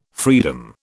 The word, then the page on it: freedom: British English